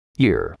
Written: ear

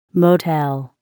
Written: motel

